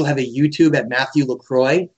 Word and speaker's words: Lacroix: That is LACROIX, or Lacroix in French. – Lacroix is the main pronunciation, is that right? – It's I guess you could call it, you can say Lacroix or Lacroix. It doesn't really matter. They're both ways to say it, but it's fine either way.